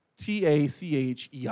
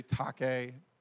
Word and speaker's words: tachei: T A C H E I, Tache. Some, some people may say Take, tache, Tache is how I pronounce it.